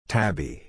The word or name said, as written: tabby